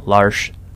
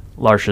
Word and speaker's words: Larche: And I'm on Facebook at Andrew, middle name Miles, last name Larsh. Larsh is spelled L-A-R-C-H-E, and go there, like the page, or me.